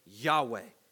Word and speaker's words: Yahweh: The vowels were added later so that it could be pronounced Yahweh.